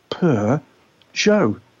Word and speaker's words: Peugeot: and all she was doing as the sign-off was pronouncing it Peugeot.